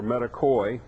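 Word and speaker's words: metochoi: We've said before that the term metakos, which is a singular, metakos or metakoi, better spell it in English, M-E-T-O-C-H-O-S or C-H-O-I, plural.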